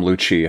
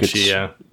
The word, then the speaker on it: Lucci: Was that Lucci or Lucy? How do you say that? – I call him Lucci. I think Lucci, it's like an Italian name.